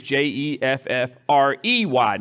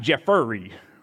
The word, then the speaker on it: Jeffrey: My name is J E F F R E Y, not E R Y. It's Jeffrey, not Jeffery, right?